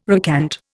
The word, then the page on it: recant: Indian